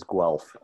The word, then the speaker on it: Guelph: The city I live in is Guelph, G-U-E-L-P-H. It tends to get mispronounced often, but I live in Guelph, Ontario, which is about 45 minutes southwest of Toronto.